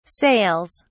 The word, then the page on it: sails: British English